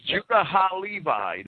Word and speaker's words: Judah HaLevi: She learned Hebrew and translated Judah, I can't just pronounce the word, H-A-L-E-V-A. – It's Judah HaLevi. That would be Judah the Levite in Hebrew, right?